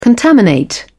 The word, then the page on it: contaminate: British English